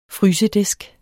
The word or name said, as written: FRYSEDISKEN